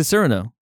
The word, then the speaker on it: Cyrano: I've always pronounced it Cyrano. – Why? – But is it Cyrano? – Yes.